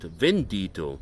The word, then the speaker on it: Bandito: It's a pronounced Vendito, so apparently the B's are pronounced as V's here in Spanish or Mexican Spanish, I guess.